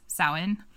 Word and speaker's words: Samhain: In Celtic Irish, it's Samhain. But it's spelled S-A-M-H-A-N because Gaelic is a very unique language.